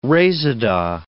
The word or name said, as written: reseda